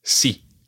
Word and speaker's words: sí: It's sí.